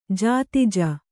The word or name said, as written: ಜಾತಿಜ